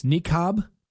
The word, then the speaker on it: niqab: Niqab? N-I-Q-A-B? – I think that's right.